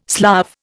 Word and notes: Slav: Indian

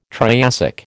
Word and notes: Triassic: American English

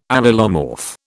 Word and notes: allelomorph: British English

